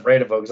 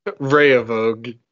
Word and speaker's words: Vrede Volk: I'm still reeling that the D is silent. the second one. The last D. – Vrede Volk.